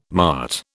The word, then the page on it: mart: British English